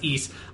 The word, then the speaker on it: Ys: Yeah, I went to the Ys Wikipedia page just to sort of confirm the pronunciation. It is pronounced Ys.